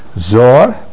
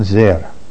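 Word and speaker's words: Zer (זר): Rabbi Yochanan Rami, Rabbi Yochanan asked the contradiction, Ksiv Zor Bakarin and Zer. The way it's written in the Torah is Zayin Resh. Zayin Resh would be pronounced as Zor. But we pronounce it Zer.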